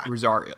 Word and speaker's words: Rosaria: Rosaria, that's how you say it.